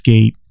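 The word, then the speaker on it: Gaite: If you want to search her at the website, it's G-A-I-T-E, but it's pronounced Gate. It's a Dutch name.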